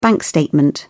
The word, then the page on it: bank statement: British English